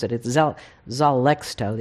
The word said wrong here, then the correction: Xarelto: It's one of those things where they marketing wanted to have an X and a Z because those make medicines very effective. Mm. So they have an X and a Z in the same name, and I can't pronounce it. But anyway, it's Xarelto.